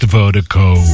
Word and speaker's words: Virdiko: And Vertigo is spelled V-I-R-D-I-K-O. Pronounced Vertigo. That's V-I-R-D-I-K-O.